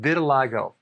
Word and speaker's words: vitiligo: I want to talk about vitiligo. Vitiligo. That's how it's pronounced.